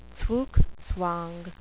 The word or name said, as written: zugzwang